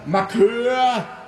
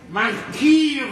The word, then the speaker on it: Marquis: Ein Herr, ein gewisser Markeur Renoir, so heißt er. – Ja, du meinst den Marquis Renoir? – Ja, was schreien Sie? Das kann man so auch aussprechen, wie ich das ausgesprochen habe.